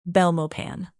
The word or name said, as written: belmopan